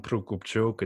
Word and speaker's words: Prokopchuk: I mean, in Ukrainian, it's Roman Prokopchuk. It's like a rolling R, but in English, yes, that's pretty much what it is.